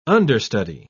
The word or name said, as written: understudy